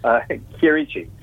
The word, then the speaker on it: Chierici: It's pronounced just the way it's spelled, Chierici. – Well, I knew it was Chierici, but because most people don't know that in Italian, CH is pronounced K, I'm sure you've had it pronounced many, many ways. – Exactly, yeah. I just say it's like Chianti.